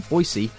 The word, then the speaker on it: Boise: Currently serves as the Vice President of Eternity Bible College's Extension Campus in Boise, Idaho.